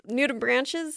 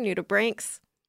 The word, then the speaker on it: nudibranch: like nudibranches sound like they should or look like they should be pronounced nudibranch, but I learned they're nudibranks.